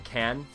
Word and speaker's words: Cannes: Cannes Film Festival? – Yes.